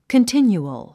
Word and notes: continual: kəntínjuəl; コンティニュアル

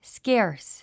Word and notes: scarce: skéə r s; スケアス

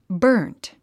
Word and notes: burnt: bə’ː r nt; バーント

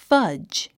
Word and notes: fudge: fʌ’dʒ; ファッジ

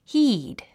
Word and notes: heed: híːd; ヒード

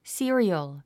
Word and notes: serial: sí ə riəl; スィリアル

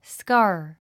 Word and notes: scar: skάː r; スカー

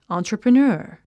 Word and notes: entrepreneur: ὰːntrəprənə́ːr; アントラプレナァ

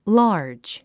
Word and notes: large: lɑ’ː r dʒ; ラージ

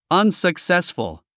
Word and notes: unsuccessful: ʌ̀nsəksésfəl; アンサクセスフル

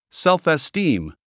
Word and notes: self-esteem: sélfistíːm; セルフェスティーム

